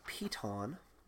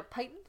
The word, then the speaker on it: piton: Piton? You mean a python? – No, a piton. – That's how you pronounce it? – That's how you pronounce it. It's a climbing tool. It's a piton. – I thought it was a python. – It is not a python. It is a piton.